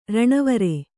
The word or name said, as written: ರಣವರೆ